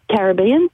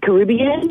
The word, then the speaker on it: Caribbean: Look, we say Caribbean, they say Caribbean.